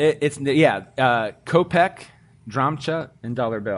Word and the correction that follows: drachma: I'm not saying that right. Drachma. D-R-A-C-H-M-A. Drachma, I guess.